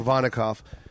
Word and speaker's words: Provodnikov: Yours against, and I can never say his name, Provodnikov.